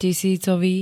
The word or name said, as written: tisícový